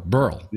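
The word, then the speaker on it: Burrell: You say it like squirrel. Burl.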